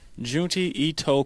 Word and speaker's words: Junji Ito: The one we decided to choose, and how you pronounce that? Is it Junito? Junji Ito Collection.